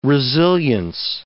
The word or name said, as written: resilience